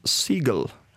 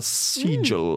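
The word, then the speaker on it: Segel: Det som er interessant, er hvem som skal regissere den det er ingen ringere enn Jason Seagull. Eller Seagull, mm. – eller hvordan det uttales. Som i måke? Som i S-E-G-E-L.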